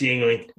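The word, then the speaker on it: Yuengling: It's a Youngling, so. – Youngling or Yingling? – I don't know how to pronounce it. – Pretty sure it's Yingling. – It is.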